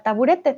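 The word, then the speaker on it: taburet (табурет): Olga dice, en ruso también tenemos la palabra taburete, pero se pronuncia taburet.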